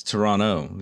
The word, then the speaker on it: Toronto: It's Toronto. You don't pronounce the T.